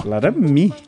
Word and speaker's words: Laramie: É ela mesmo, Tô falando de uma Dodge Ram como é que se pronuncia isso aqui? É Laramie? Laramie.